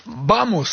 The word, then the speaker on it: vamos: Vamos, ça se prononce comme ça. – Tout à fait. – Le V se prononce B en espagnol, je sais pas, je parle pas espagnol.